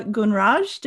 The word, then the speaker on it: Gunraj: We'll go to Andrea Gunraj.